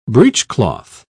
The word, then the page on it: breechcloth: British English